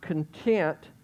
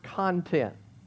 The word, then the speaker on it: content: It's the first time I ever saw the word content and realized that it looks just like the word content. Just like spelled the same, except you put the emphasis on a different syllable in this case.